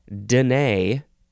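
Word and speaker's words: Dené: The next word is denay or denay, but the emphasis is still on the second syllable. This is spelled capital D-E-N-E, and both E's have the accent that goes boop, denay.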